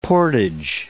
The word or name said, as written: portage